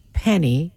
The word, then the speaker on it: penne: Benny's penny, if you spell penne, you pronounce it that way.